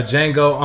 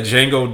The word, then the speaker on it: Django: Um, a, a, Django Unk because the D is silent in both? Pretty much.